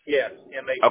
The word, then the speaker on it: Mathis: Yes, M-A-T-H-I-S. – Okay. Got it. And Brian, do you spell that B-R-I-A-N? – Correct.